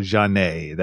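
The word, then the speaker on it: Zhané: Finally, in 1994, we got the Philadelphia R&B duo Jhane. That's Z-H-A-N-E with an accent.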